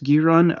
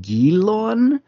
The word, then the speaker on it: Guiron: So, or I think it's usually pronounced Guiron, arguably Guilon. So the idea is it comes from the French for guillotine.